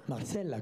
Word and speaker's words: Marzella: Ah, je ne sais pas si je le dis bien, c'est Marzella ou Marcella Marcella. – Marcella quand même, même si c'est un Z.